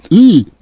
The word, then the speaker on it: Ai: I think you pronounce AI as E.